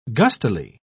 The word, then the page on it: gustily: British English